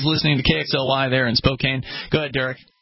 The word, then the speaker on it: Spokane: Even though it's pronounced Spokane, if you're from there.